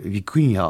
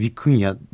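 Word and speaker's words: vicuña: E, bu vicunaya baktım ben biraz ilginç e, vicunya aslında hmm. – e, İspanyolca e, vicuna diye yazılıyor ama e, vicunya diye okunuyor.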